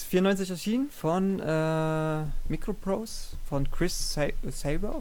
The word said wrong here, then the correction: Sawyer: oder wie wird er ausgesprochen? Sawyer. Sa Sawyer, genau.